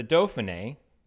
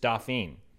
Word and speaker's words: Dauphiné: My joke that will probably only land if you live in New Orleans is is that it's the Dauphiné, right? The hmm. – Criterium mm. de Dauphiné. But in New Orleans, we have a street that is spelled the same way, and it's just Dauphine.